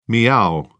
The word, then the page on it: miaow: British English